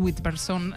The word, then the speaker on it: Witherspoon: Reese Witherspoon, Laura Dern o Meryl Streep.